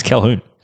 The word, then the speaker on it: Colquhoun: Colquhoun. – It's Calhoun. – I know. I said it wrong, okay? – I just like the name.